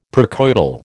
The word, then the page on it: precoital: American English